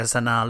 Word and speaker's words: Sanaa: And then we see Sanaa Lathan. I will have to say, I have never exactly known how to pronounce her name. It was pointed out that it's simply Sinatra without the tra.